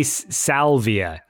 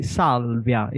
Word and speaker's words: Salvia: I say Salvia. – Ah, okay. – Sal, like that, but you say Sol. – I say Salvia. It's open, the A. The A is open.